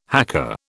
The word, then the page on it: haka: British English